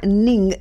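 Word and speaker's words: Ng: Tras esa visita oficial inicial, Rosa Ning, N-G es el apellido, no sé cómo se pronuncia, si será Non o Ning. – Ning. – Ning, ¿verdad? Sí.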